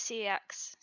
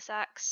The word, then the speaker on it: CeX: So I was working at, some people pronounce it CEX, some pronounce it SEX, some pronounce it Computer Exchange, so I'll go with that.